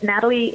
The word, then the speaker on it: Nathalie: So it's just my name, but Nathalie is spelled a little different. It's with an H. It's N-A-T-H-A-L-I-E.